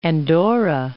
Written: Andorra

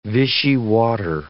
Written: Vichy water

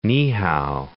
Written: Niihau

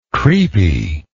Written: creepy

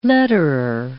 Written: letterer

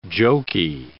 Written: joky

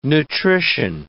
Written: nutrition

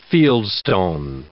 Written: fieldstone

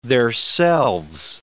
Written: theirselves

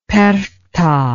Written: perchta

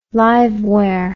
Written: liveware

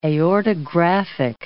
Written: aortographic

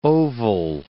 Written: oval

